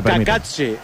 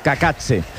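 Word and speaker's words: Cacace: La caída de Liberato Kakatse. He tenido que buscar cómo se pronunciaba este futbolista para no cometer errores. Y según la IA es Kakatse. – Kakatse.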